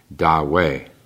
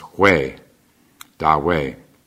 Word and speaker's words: Ta Hui: The T is pronounced like a D, Da, and then the second word H-U-I. It's not we, as you'd think, but it's, I'm told, is pronounced Hui, Da Wei.